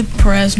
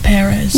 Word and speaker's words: Perez: I say Perez, not Perez. – Yeah, Perez was probably the worst pronunciation I could have gotten of that.